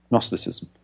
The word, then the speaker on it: Gnosticism: It's the foundation of the modern word knowledge, of course, Gnosis, which is why we've got a silent K at the beginning of knowledge. Yeah, the same as the silent G at the beginning of Gnosticism.